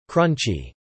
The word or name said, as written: crunchy